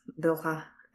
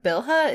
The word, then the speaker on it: Bilha: And then Billa is, Bilha, I keep saying her name wrong, but Bilha is just awesome.